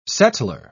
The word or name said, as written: settler